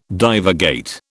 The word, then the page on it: divagate: British English